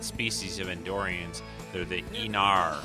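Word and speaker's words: Aenar: And I'm sure they'll be saying that word a few times in this episode, but that is how it's pronounced, the Enar.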